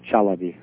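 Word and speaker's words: Chalabi: There's one headed by a man named Shalabi. Am I pronouncing that right? – Yeah, Dr. Ahmed Shalabi.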